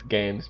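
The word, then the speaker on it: GAEMS: It looks like it says Games, but it's Games because... – Yeah, it's G-A-E-M-S.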